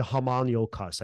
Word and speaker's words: homunculus: Like the homunculus. I think that's how you homunculus. Homunculus. – There you go. That's how you pronounce it, right?